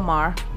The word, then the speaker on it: Maher: Uh, Bill Bill Maher Maher or Murr Murr. How you pronounce this shit? Bill Mar.